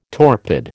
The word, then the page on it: torpid: American English